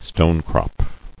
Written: Stonecrop

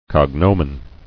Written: Cognominal

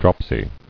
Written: Dropsical

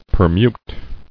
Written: Permutable

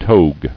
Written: Togue

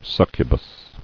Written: Succubi

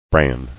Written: Branny

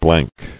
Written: Blankness

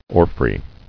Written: Orfrays

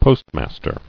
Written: Postmaster

